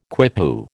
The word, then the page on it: quipu: American English